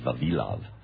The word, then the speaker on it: Vavilov: Vavilov. My Russian friends pronounced it Vavilov.